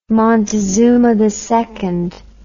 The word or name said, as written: Montezuma II